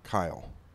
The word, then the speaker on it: Kyle: The one thing I do remember is I was on a baseball team, and the coach couldn't pronounce my name, Kyle. So he pronounced it Cal.